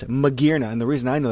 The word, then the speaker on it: Magearna: Is it Magearna? – It is Magearna, yeah.